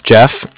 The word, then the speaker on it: Jeff: My name is Jeff, J-E-F-F, and I'm calling from the Bank of Cardiff. Cardiff is spelled C-A-R-D-I-F-F.